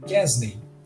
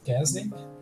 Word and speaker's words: Quesnay: Kesney, né? É, eu não sei se você se pronuncia assim, Kesney, né? Mas se você quer anotar aí, se escreve assim: Q-U-E-S-N-A-Y, né?